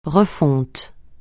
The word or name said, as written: refonte